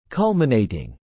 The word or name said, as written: culminating